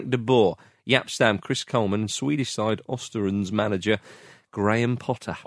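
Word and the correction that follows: Östersund: Ostersons. And you were a host of On the Continent, so you knew the correct way to pronounce that.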